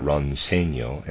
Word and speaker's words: Roncegno: Footnote, Ronseno, I'm pronouncing that Ronseno, and it's spelled R-O-N-C-E-G-N-O, and Levico, L-E-V-I-C-O, are mineral waters from the Dolomites, limestone mountains in northern Italy.